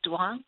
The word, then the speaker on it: Duong: The word is Duong. It's spelled D-U-O-N-G.